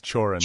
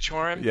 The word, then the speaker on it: Cioran: Emile Cioran, I think. – Chorin. It's kind of, I think it's Chorin. – Chorin. Is it Chorin? – Yeah.